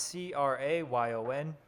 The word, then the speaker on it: crayon: How do you pronounce C R A Y O N, otherwise known as crayon, which is pronounced, which is how you should pronounce it if you're smart. Okay, so do you pronounce it with one syllable, rhymes with man, with two syllables, sounds like crayon, with two syllables, where the second syllable rhymes with dawn, sounds like crown, other, crayon.